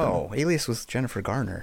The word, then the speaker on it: Alias: Alice Smith and Jones premiere on ABC. – Ooh. – Alice Smith and Jones? – Is that how you pronounce it? A-L-I-A-S? – Oh, Alias.